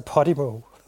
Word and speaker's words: Podimo: Jeg ved ikke, om det har været den største udfordring, men en af udfordringerne har været, hvordan vores navn blev udtalt, og det udtales altså Podimo.